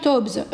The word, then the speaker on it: Taubes: Est-ce que tu recommandes les ouvrages de Gary Tobbs et de Dr. Lustig Question de Nin Mimil. Alors, Mimil, Gary Taubes, euh, pour celles qui nous écoutent, ça s'écrit T-A-U-B-E-S.